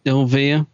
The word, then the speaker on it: Olvir: I mean, Dominic Guard, who played how do you say his name? Olvir, I think. – Olvir. Yep. He starts to get to know Nyssa a bit, and you think that might develop.